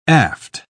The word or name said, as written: aft